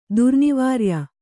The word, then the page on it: ದುರ್ನಿವಾರ್ಯ: kannada